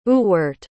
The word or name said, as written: Ewart